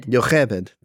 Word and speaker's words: Jochebed: So we find out from this that Moses' parents' names are Amram and Jochebed, I don't know how you say it, but anyway, I guess, you're on this week's episode, so you know these things. – So try this. Jochebed.